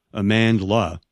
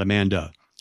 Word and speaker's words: Amandla: But they cast as the lead in Acolyte an actress by the name of Amandla, with an L, not Amanda, Amandla Stenberg.